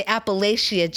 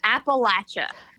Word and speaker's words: Appalachia: I have a tendency to say Appalachia. Okay. How is it pronounced? – So it's Appalachia. – Appalachia. – You're holding an apple in your hand and you're going to throw an apple at you.